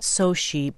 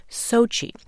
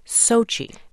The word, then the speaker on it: Sochi: The name of the city is pronounced not Sochi, but exactly as written. That would be Sochi, just as Mr. Marchenkov writes, chi as in chicken, with the stress on the first syllable, Sochi.